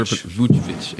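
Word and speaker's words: Vučević: Never, Vucevic.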